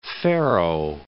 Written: Ferro

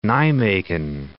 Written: Nijmegen

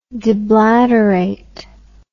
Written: deblaterate

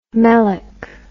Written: melick

